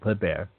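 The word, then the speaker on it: Kléber: excuse me, that's how it's said, Kleber, who was born in Strasbourg in 1753.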